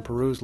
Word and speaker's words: Peyroux: Madeline Peru spells the name P-E-Y-R-O-U-X, but pronounces it like the country.